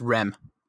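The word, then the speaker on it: Reims: I don't know how to say it. It's R-E-I-M-S. – Rem. It's Rem.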